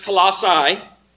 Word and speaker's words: Colossae: Paul was writing to a relatively small church in a relatively small city called Colossae, or can also be pronounced Colossae.